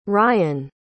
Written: Rian

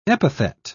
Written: epithet

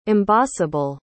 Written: embossable

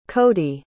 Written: cody

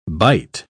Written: bight

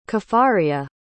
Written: caffaria

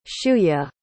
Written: shuya

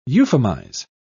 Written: euphemize

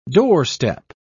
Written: doorstep

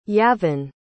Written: Yavin

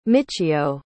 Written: michiyo

